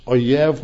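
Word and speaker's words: oyev (אויב): And it is pronounced oyev when you put the vowels into it, but it is aleph, yud, vav.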